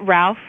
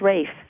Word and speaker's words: Ralph: Is it Ralph? – I think it's Rafe. – That's what I think it is too, Rafe Fiennes.